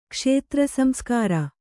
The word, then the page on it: ಕ್ಷೇತ್ರಸಂಸ್ಕಾರ: kannada